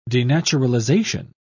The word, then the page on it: denaturalization: British English